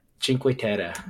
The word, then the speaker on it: Cinque Terre: Cinque Terre.